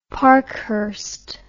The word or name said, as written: Parkhurst